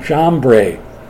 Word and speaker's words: Jambres: As Jannes, J-A-N-N-E-S, and Jambres, J-A-M-B-R, long E-S. Jannes and Jambres resisted Moses.